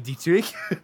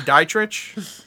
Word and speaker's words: Dietrich: Do you have to say it like that? – That's how it's said. What do you want me to say? Dietrich? – No, I'm just.